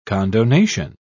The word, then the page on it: condonation: British English